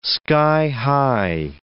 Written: sky-high

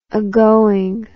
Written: agoing

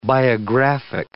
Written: biographic

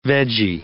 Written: vegie

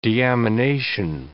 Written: deamination

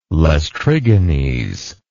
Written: Laestrygones

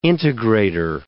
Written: integrator

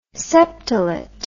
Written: septolet

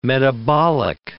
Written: metabolic

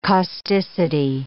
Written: causticity

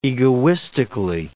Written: egoistically

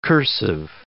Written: cursive

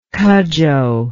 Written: cudjo

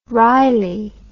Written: reilly

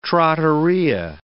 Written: trattoria

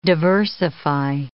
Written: diversify

